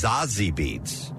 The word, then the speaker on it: Zazie Beetz: And here's star Zazie Beetz. Actually, I think it says here pronunciation is Zazie Beetz.